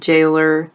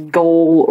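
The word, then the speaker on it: gaol: Jail and jailer. When I was reading it... the book, I wanted to read it as goal or goalers, but no, you just pronounce it as jail and jailer.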